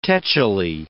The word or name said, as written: tetchily